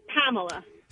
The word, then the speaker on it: Pamela: It's just a long A. Instead of a Pam, like a Philadelphia accent, it's a Pamela. Long A would be a Pamela.